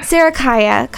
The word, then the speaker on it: Sarikaya: Sarakaya.